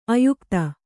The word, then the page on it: ಆಯುಕ್ತ: āyukta